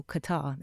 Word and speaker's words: Qatar: So we were living in Qatar, a place people call Qatar, they pronounce it wrong,